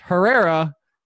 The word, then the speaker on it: Herrera: And the first person I went to was Herrera. And it's pronounced Herrera.